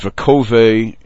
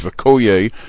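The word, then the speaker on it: Vekoye (וקוי): He sings it Vekove. It's misread Vekove. The correct word is Vekoye.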